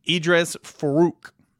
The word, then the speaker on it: Edrees Farooq: And that is how you say his name. Edrees Farooq.